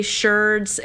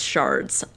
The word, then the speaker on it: sherds: I've heard Americans say sherds and the rest of the world says shards.